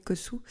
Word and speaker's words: Kotsou: Euh, j'avais évoqué une fois, je crois, dans un une publication Instagram, je ne trouvais plus, euh, une intervention d'Ilios Kotsou, Je prononce correctement, oui, Kotsou,